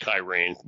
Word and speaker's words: Cyrene: There's the Greek town, sometimes it's pronounced Kyrene, but it no longer exists.